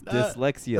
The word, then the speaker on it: dyslexia: The dyslexis. – How did I say it? – Dyslexis, like the car. It's dyslexia.